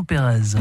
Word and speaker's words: Perez: Quant à lui, pas de doute sur la prononciation, c'est juste Vincent Pérez.